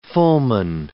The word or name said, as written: fulmine